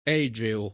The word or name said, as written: Egeo